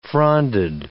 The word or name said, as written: fronded